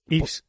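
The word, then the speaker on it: Yves: que protagoniza Ice Montan, Ives, Ives. Montan, depende de si lo quieres pronunciar bien o mal, depende un poco.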